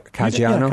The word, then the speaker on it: Caggiano: And Bishop Frank Caggiano, if I pronounce that right. Caggiano? – Yeah, Caggiano. Yeah.